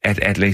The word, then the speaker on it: Adlai: progressive synes, at Adlai Stevenson burde blive partiets kandidat.